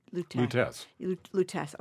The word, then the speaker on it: Lutèce: The L-U-T-E, Lutèce.